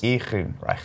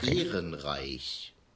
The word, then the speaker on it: Ehrenreich: Of the name is Ehrenreich. – Ehrenreich. – Ehrenreich.